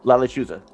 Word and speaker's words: Lechuza: Also, help me out with the pronunciation. Lechuza. – Yeah. – Uh-huh. – Mm-hmm. – La Lechuza. – Excellent. Yeah. Yep. There you go, man.